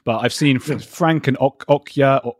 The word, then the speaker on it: Okja: Okja. Thank you. – Okja. Fantastic. Okja. I think I said that right. Okja. – Okja. Like the oak tree. – Right. – With a J at the end.